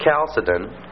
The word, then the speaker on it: Chalcedon: Remember, it's pronounced with a hard CH. Chalcedon, 451.